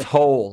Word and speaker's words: Hoel: It's Hoel, like a hole in the ground.